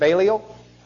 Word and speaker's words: Balliol: Is it Balliol? Okay. I paid good money for him to learn that. Actually, I think Becky wrote the check. He's master of Balliol College.